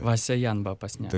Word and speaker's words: Васяян Бапасняк: А може «Васян Бапасняк»? Там два «я», але як читається? – «Васяян Бапасняк». – Таке «Васяян Бапасняк».